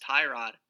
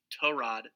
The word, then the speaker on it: Tyrod: Uh, Tyrod Taylor is not pronounced Tyrod. Uh, one of his teammates came out and let us know that it's actually pronounced Torod Taylor.